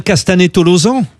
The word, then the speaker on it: Castanet-Tolosan: On prononce bien Castanet-Tolosan? – C'est ça.